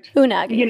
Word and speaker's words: Unagi: The Yagi. Am I saying that right? – Unagi. – Unagi. – Who is following them? The Unagi. – No, it's funny that you keep saying it wrong.